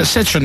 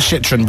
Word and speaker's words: Citroën: I'm driving a Citroën minibus. – Nice wheels. It's a Citroën, not a Citroën, by the way, to let you know.